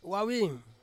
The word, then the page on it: wawin: Ansus